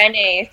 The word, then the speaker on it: Brené: Did you say, you didn't say Brenna Brown. Who's the author? – No, Brené. So it's B-R-E-N-E and it's got like an accent on the last E.